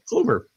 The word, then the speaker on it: Kluber: Corey Kubler. – Yes. – Gosh, I know. I always put the L in the wrong place. – Kubler? – Kluber. – It's Corey Kluber, yeah.